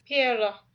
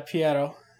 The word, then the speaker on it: Pierrot: And I'm sure I'm not saying it properly. – Pierrot. – Yeah, Pierrot.